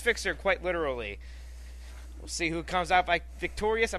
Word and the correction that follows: victorious: I'm about to say victorious. I was putting the emphasis on the wrong syllable.